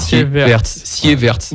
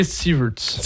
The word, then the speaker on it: Sieverts: Alors, ça s'écrit S-I-E. V-E-R-T-S. – Voilà. – Sievertz. – Ouais, en anglais, Sievertz. – Sievertz. Voilà. Il va manquer des lettres. Là. – Non, c'est Sievertz.